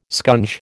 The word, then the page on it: scunge: American English